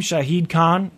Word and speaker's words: Shahid Khan: The Jacksonville Jaguars guy is at 23, and that's, how do you say his name? Shahid Khan? – Yeah. – Right? Does that sound right?